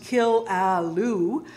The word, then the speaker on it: Killaloe: The old German settlement, pronounced Kill-a-loo, obtained its weather station more or less by accident.